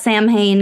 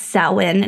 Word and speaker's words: Samhain: So we're just going to call it Samhain because we're going to butcher it and completely westernize this name. But yeah, it's Samhain in Gaelic.